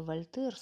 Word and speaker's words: Вольтер: Истинный энциклопедист своего рода Вольтер с ударением, правда, на первом слоге, он списал, не скупясь тьму страниц, всегда готовый обхватить, как свернутый ковер, как свер...